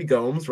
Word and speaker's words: Gomes: And he went with Henry YYZ, your boy Henry Gomes, rhymes with Holmes from Bransky Slack.